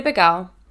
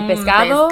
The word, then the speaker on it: pescado: So, We'll say like freco, esa cosa está freco, and then instead of saying fresco. So I didn't know these words were existent growing up. They're like, my mom, like, tú quieres pecado? instead of saying pescado.